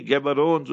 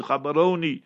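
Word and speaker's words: Gaborone: Khabaroni, the people say Gaborones with proper pronunciation is Khabaroni.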